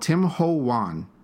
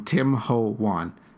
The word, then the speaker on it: Tim Ho Wan: Okay, so it's called Tim Ho Wan. It's exactly how it sounds, except Ho is spelled H-O. Tim Ho Wan.